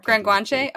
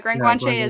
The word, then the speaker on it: Gran Guanche: How do you pronounce that? Grand Guanche? – No, Grand Guanche. Oh yeah, Grand Guanche is that's a ultra on the Canary Islands.